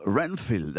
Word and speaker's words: Renfield: Ce matin, votre indispensable, c'est un film intitulé Renfield, ça se prononce comme ça ? – Renfield, absolument, c'est un film de genre